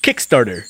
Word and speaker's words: Kickstarter: que se llama Kickstarter. ¿Cómo se dice en inglés? ¿Bien? – Kickstarter. – ¡Ándenle! ¡Que vean! No andaba tan lejos.